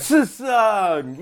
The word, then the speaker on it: Tsung: First of all, I don't even want to tell you how I pronounced his name when I was a kid. You know that T got pronounced hard. – Tsung, yeah.